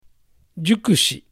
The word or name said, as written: Jukushi